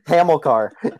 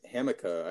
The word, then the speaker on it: Hamilcar: It's actually not even Hamilcar. It's Hamica. I saw that pronunciation. – Oh, uh, I guess the L is silent.